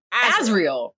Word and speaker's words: Azriel: So it's pronounced Azriel.